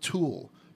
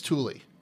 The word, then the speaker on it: Thule: I kept on calling it Thule, but it's Thule.